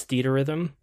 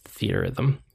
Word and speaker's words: Theatrhythm: She says that she loves Theater Rhythm. I think it's actually pronounced Theatrhythm.